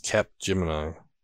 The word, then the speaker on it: Capgemini: CAPGE Mini? How do you pronounce that? – I don't know. – Never heard of this company. They're a global leader in consulting. – Oh, Capgemini. – Oh, I was trying to put it mini, like Cage Mini or something.